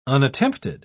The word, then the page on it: unattempted: British English